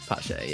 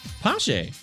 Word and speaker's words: Pache: Is it Pache? – Pache, yeah. – Pache. – Yeah, so kind of pronounce it like there's an S in there, like Pache.